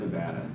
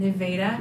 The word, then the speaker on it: Nevada: By the way, everybody, not Nevada. – Yeah, not Nevada. – Not Nevada. – It's pronounced Nevada.